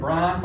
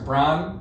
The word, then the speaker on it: Brian: Brian Forrester. Brian rhymes with wine, you know?